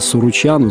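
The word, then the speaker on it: Suruceanu: что фамилия Суручану заканчивается на «у», а произносится как Суручану, Челентану, ну и так далее.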